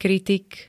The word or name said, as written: kritik